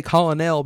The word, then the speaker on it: colonel: There are no R's in that word. – English language is the hardest. – But that's how you say it. You can't say colonel because people, what the hell are you talking colonial. About? Colonial. That's I-A-L, and okay.